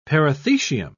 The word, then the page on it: perithecium: British English